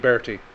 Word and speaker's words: Berte: The maid, Bertie. – Yep. – B-E-R-T-E. Am I saying that right? – I think so, yeah.